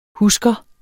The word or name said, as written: husker